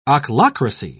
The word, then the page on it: ochlocracy: British English